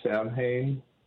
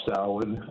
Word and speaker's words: Samhain: You said Samhain. – Yeah, I mispronounced it. – Yeah, it's called Samhain.